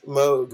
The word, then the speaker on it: Moog: It's Moog or no, or it's spelled like no, right? – Moog. Yes, Moog, right. Like rogue.